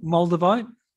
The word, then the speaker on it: Moldavite: Moldavite.